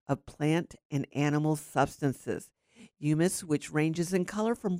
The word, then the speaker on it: humus: And the dirty word of the day is humus. It's pronounced as if it has a Y in its spelling, humus, H-U-M-U-S.